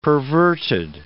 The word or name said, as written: perverted